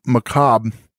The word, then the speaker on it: macabre: I think a more important thing to talk about in this episode would have been how macabre does not look like it should be pronounced macabre. – No, why is that R there? – Macabre!